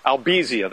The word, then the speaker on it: albizia: So the threshold for those trees, albizia, that's the correct pronunciation, albizia trees, it didn't take as much wind as you would with a typical hardwood back on the mainland.